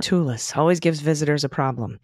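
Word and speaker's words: Tchoupitoulas: Oh, Chupatulus? Pronounced Chupachopatulus. I was close. – You were very close. – I was so close. – There's a T at the beginning of this word, by the way.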